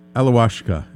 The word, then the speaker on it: Alawashka: Let's talk about Alawashka. Did I pronounce that right? – Yes. So, yes, you did beautifully.